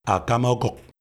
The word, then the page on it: Akama okuk: LHHHM